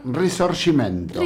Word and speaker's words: Risorgimento: Risorgimento.